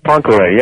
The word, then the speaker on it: Poincaré: Poincaré, yeah.